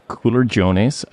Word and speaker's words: culurgiones: And I worked with our waiter to get the pronunciation down, and he gave me the thumbs up. So culergiones, I think that's right. So these are dumplings.